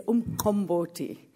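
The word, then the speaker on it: umqombothi: So the pronunciation, which hopefully I'll get right, because sometimes I do and sometimes I don't, is umkomboti. So you can practice that later on, you know. So the Q is like a... So it's umkomboti.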